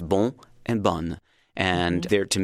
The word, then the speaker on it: bon: The masculine is the word B-O-N, bon, and then the feminine has a double N and an E, B-O-N-N-E. And so the difference is bon and bun, bon and bun.